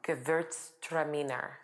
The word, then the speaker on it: Gewürztraminer: Okay. Say that again. – Gewürztraminer. – Gewürztraminer. Yes. Did I say that right? Yeah.